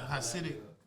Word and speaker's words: Hasidic: They like the Hasidic. I might be saying it wrong, but. – No, that's correct.